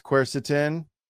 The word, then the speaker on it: quercetin: It's quercetin. Okay. That's how you spell it. Q-U-E-R-C-E-T-I-N.